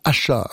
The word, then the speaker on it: Asa: Ça s'écrit Asa, A-S-A, mais ça se prononce Asha, artiste tout à fait intéressante.